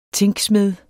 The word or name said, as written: Tringa